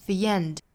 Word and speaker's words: the: 注意这个 the 的变音哈它从这个 a 变成了 e 的发音再带出一个后面的小小的 ye 的音 the end